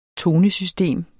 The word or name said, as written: tonesystem